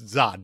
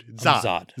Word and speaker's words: Zod: You said it wrong. – He says, no, it's Zod, dude. Zod. Z. Zod.